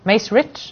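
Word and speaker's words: Macerich: Maserich. – Yeah, it's a silent CH. Silent G. Maserich.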